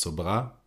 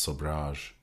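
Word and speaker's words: Sobhraj: Und die Franzosen sagen tatsächlich einfach Charles Sobra. Und im Amerikanischen sagen sie halt Charles Sobrage. Ich halte mich an die französische Variante. Na jedenfalls, Charles Sobra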